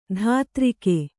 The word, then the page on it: ಧಾತ್ರಿಕೆ: dhātrike